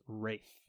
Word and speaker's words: Ralph: It's Ralph. That's how you say it in England. In the United States, you say Ralph.